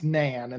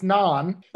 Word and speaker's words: naan: And then you said to get some naan bread, which of course I pronounced nan, and then I was corrected by my partner who said, no, it's naan, but that has garlic in it.